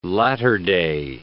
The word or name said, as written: latter-day